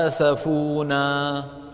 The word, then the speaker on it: آسفونا: شوف الهمزة وين مكتوبة على السطر. إذن الألف اللي بعدها تنطق. فلما آسفونا.